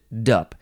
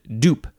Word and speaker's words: dupe: You can't pronounce it dup. You have to pronounce it dupe, because it's duplex and duplicate. Wow, wow. The next word is dupe. D-U-P-E, first form, noun from 1681.